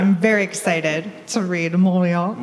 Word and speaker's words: Mauriac: Did I say it right? – Yeah. – Because I only know how to pronounce things when I read them. – I mean, if you could sort of say ho-ho-ho at the same time, that'd be... – Like Mauriac? – Yeah, that's right.